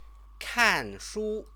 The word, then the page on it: kanshu: Chinese